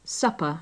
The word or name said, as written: supper